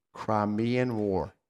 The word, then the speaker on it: Crimean: Florence made a name for herself while serving as a trainer of nurses during the, let me pronounce it correctly, Crimean War. – Oh my God, he said it right.